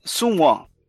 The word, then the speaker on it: Sun Wen: Como não tem? Eu tô aqui, pô. Então, como que, como que é o nome dela? É, Sun Wen.